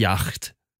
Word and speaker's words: yacht: Så när han fick reda sen på att hon skulle komma med sin rika familj i deras jacht. säger man yacht? Du som är bra på tyska uttal. Yacht. Yacht, säger man då ja.